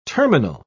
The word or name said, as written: terminal